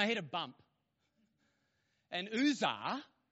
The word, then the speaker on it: Uzzah: Uzzah.